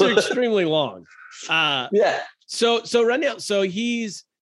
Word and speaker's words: Ranil: Ranil. Ranil.